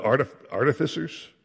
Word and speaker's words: artificers: They gave it to the workmen that wrought in the house of the Lord to repair and amend the house, even to artificers. How do you say that word, Mark? Huh? Artificers, okay.